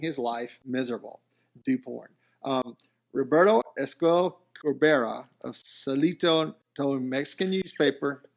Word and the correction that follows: Saltillo: Did I say it right? – No, but you can go with it. How do I say it? How do you say it? – Roberto Esquivel Cabrera of Saltillo.